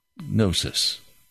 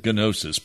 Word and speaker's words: gnosis: Gnosis. The G is not pronounced. Some say gnosis, but that's not correct. It's gnosis. Now, the word in the Greek is not gnosis.